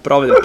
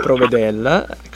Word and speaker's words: Provedel: E allora, siamo in chiusura, abbiamo giusto un minuto per salutare e ringraziare Ivan Provedel, mi raccomando, chi ci sta ascoltando, non Provedel, Provedel, quindi prendere nota e non sbagliare l'accento,